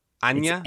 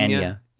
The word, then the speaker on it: Anya: Anya? – It's Anya.